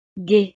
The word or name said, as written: gaie